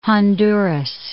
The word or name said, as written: Honduras